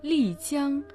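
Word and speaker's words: Ли Цян (丽江): Ли Цян – это словосочетание состоит из двух иероглифов. Ли означает «красивый», произносится в четвертом тоне, а Цян – «река», произносится в первом тоне. – Лидзян славится своей естественной красотой и древней историей.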